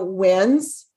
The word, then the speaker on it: Wednesday: So Wednesday, what they don't realize is the D is silent in the word Wednesday.